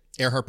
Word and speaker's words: Erhardt: Earhart Perkins. – Earhart Perkins. And Earhart is spelled E-R-H-A-R-D-T.